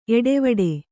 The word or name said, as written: ಏಟವೀಟ